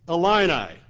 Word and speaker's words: Illini: "Accent the second syllable and pronounce it with us. Illini. Good. Try again until it fits the tongue as well as Illinois."